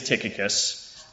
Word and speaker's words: Tychicus: So the first thing I want to say is that there's been a little bit of conversation about how Tychicus or Tychicus is pronounced, and in fact it is Tychicus if you go back to the Greek. And more specifically, it's Tychikos with a K-O-S on the end of it if you go to the original translation.